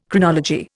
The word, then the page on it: Chronology: Indian